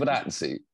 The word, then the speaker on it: Giovinazzi: Giovinazzi.